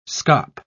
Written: scop

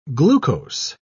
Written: glucose

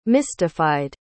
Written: mystified

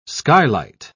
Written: skylight

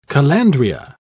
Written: calandria

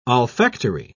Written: olfactory

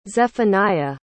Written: zephaniah

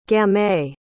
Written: gamay

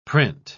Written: print

